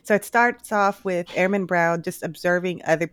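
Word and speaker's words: Hermann: It was not Herman, but Airman.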